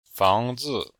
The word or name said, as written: fang2zi5